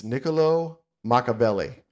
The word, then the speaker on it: Niccolò Machiavelli: It's Niccolò Machiavelli.